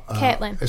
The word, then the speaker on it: Caitlyn: Now, Caitlyn. – It's pronounced Caitlyn, is it? Okay.